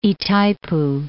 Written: Itaipu

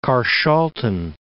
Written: Carshalton

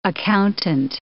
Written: accountant

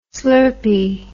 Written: slurpee

